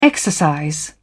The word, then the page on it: exercise: British English